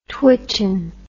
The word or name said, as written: twitchen